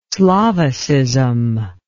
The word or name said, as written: slavicism